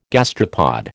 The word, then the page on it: gastropod: American English